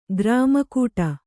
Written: ಕೂರ್ಮೆಗೆಡು

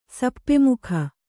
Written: ಸಪ್ಪೆಮುಖ

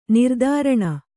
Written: ನಿರಾದರಣೆ

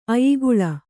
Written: ಅಯಿಗುಳ

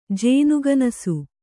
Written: ಜೇನ್ಗನಸು

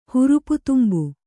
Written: ಹುರುಪುತುಂಬು

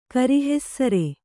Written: ಕರಿಹೆಸ್ಸರೆ

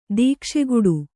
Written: ದೀಕ್ಷೆಗುಡು

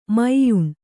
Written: ಮ್ಯಾನಾ

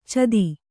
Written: ಛದಿ

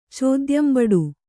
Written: ಚೋದ್ಯಂಬಡು

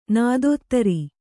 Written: ನೀತಿದೂರೆ

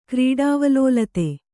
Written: ಕ್ರೀಡಾವಲೋಲತೆ

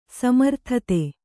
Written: ಸಮರ್ಥತೆ

